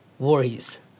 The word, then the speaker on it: Voorhees: Jason Voorhees. Uttalar jag rätt? Voorhees. Mm. Han är redan inne i huset och mördar.